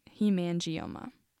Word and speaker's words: hemangioma: The second kind of vascular birthmark is a hemangioma, which is not spelled the way you think it would be, but I looked up the pronunciation, so hopefully I'm right. Hemangioma.